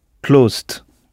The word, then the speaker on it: closed: la terminaison « ed » se prononce « de », parce qu'on aurait du mal à prononcer « closed ».